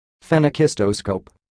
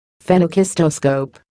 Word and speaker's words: Phenakistoscope: It's a very annoying word, and it is pronounced Phenakistoscope. – Phenakistoscope.